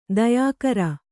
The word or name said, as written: ದಯಾಕರ